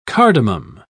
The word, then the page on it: cardamum: British English